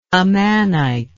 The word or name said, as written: amanite